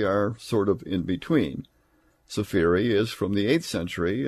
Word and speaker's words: Sefire: And Sefiri is S-E-F-I-R-E. It looks like it should be pronounced Seafire, but it's Sefiri.